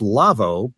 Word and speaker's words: LAWO: Now, it's pronounced Lavvo, but it's spelled L-A-W-O because it's a German company.